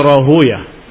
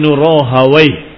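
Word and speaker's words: Rahawayh: Atau biasa ahlul hadith membacanya Rahuya, menghindari kata wai. Naftawai, Zanjawai, Rahawai, Sibawai. Semua itu wai-nya adalah katanya, wallahu alam, adalah nama syaitan. Sehingga mereka menghindari kata wai dengan kalimat rahuya, sibuya, sibawai dengan sibuya, naftawai dengan naftuya.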